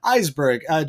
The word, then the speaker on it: Isberg: This is episode 2 of Trailblazing Terror, and today we'll be talking to John Isberg. John, am I saying that correctly? – Oh, yeah. Yeah, thanks. Yeah, Isberg. Yeah, that's good.